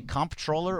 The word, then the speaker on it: comptroller: Do you say comptroller or is it controller? – I think it's just controller. – I think the P is silent. Comptroller.